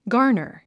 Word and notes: garner: ɡάːrnər; ガーナァ

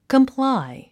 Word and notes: comply: kəmplái; コンプライ

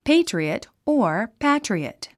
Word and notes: patriot: péitriət; ペイトリオット

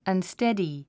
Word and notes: unsteady: ʌnstédi; アンステディ

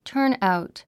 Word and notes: turnout: tə́ː r nàut; ターンアウト

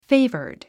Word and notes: favored: féivərd; フェイバァド

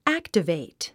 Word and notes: activate: ǽktəvèit; アクタベイト

